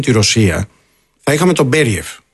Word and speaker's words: Μπεριέφ: πού τονίζεται πρώτα απ' όλα για να το λέμε και σωστά. – Νομίζω το λένε Μπεριέφ, λοιπόν. – Νομίζω τώρα από ό,τι έχω διαβάσει, τέλος πάντων έχω δει να τονίζω στον Μπεριέφ.